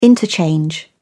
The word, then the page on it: interchange: British English